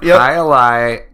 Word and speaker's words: Hialeah: Hialeah India Pale Ale from Cigar City Brewery in Tampa, Florida.